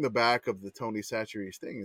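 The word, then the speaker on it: Chachere's: We always called it Chachers in my family. – I almost want to do the Louisiana pronunciation game with Katie here if she pulled the Tony Chacha. – I know some of it. I have an aunt who lives in New Iberia, but I don't know. – It's Tony Satcheries.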